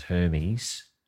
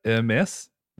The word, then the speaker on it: Hermès: Matthias Gerond Hermès, heir to the French fashion house Hermès, which I forthwith shall pronounce as Hermes, because that's what we would do.